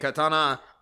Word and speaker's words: katana: As Japanese do not have separate plural and singular forms, both katanas and katana are considered acceptable in forms of English.